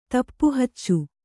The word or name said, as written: ತಪ್ಪುಹಚ್ಚು